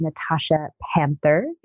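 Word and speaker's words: Natashia Pandther: And you can find me, the best place to find me currently is through Instagram, which is Natasha Panther. It's spelled differently, which is N-A-T-A-S-H-I-A.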